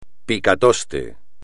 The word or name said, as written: picatoste